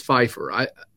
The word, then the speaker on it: Pfeifer: And I noticed that I've been leaving the P silent, but you said Pfeifer, so... – Yeah, for some reason, I've never figured that out. You know, everybody says Pfeifer. That's just how everybody pronounces their this name. But my family, my little group of our tribe, we might say, we pronounce that P.